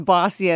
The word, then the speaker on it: Bawsi: Tell me about Bossy, spelled B-A-W-S-I, and I love that it's pronounced Bossy as in bossy little girls, and as though that's a bad thing, right?